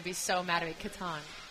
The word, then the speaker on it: Catan: I've especially noticed that is it Settlers of Catan has become quite intense. It's Catan, but yes.